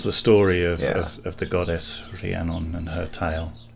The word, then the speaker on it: Rhiannon: It's awkward because it's Rhiannon. It's kind Rhiannon. Of so because there's an H in there, yeah, and a sort of rolled R.